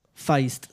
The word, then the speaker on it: Feist: Eh, Feist se pronuncia.